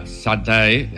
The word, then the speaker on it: Sade: Sade, o como se pronuncia Satay,